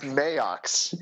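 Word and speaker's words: Maax: It's Max with two A's. – It's pronounced Mayox, like Mike Mayox. – I thought it was Max.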